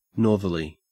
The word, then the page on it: northerly: Australian English